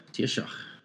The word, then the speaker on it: Taoiseach: He was a Taoiseach. Taoiseach. – Okay. I figured I was pronouncing it wrong.